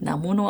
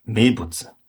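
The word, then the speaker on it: Mebutsu (名物): Hatte ich das halt, also die Kanji waren richtig, also die japanischen Schriftzeichen, die kann man auch einzeln so lesen, aber es wird halt nicht Namono ausgesprochen, sondern Mebutsu.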